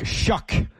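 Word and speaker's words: Shough: How do you pronounce it? Shuck? – Shuck. – Wow, doesn't look like Shuck on paper.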